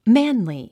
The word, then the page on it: manly: mǽnli; マァンリィ